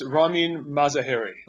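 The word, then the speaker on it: Ramin Mazaheri: No, but very few people do. It's Ramin Mazahari. – Oh, Mazahari. Okay, the accent's on the third syllable.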